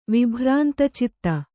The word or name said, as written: ವಿಭ್ರಾಂತಚಿತ್ತ